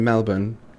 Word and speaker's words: Melbourne: It's Brisbane and Melbourne. You know, that's how I would say Brisbane and Melbourne. That's kind of what I did, right? I think I did it right. That's how you say it in Australia, Fallout.